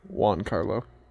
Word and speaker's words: Giancarlo Esposito: Giancarlo Esposito? Is that right? – Giancarlo.